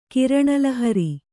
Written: ಕಿರಣಲಹರಿ

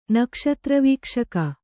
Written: ನಕ್ಷತ್ರವೀಕ್ಷಕ

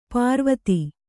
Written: ಪ್ರವಾದಿ

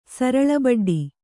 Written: ಸರಳಬಡ್ಡಿ

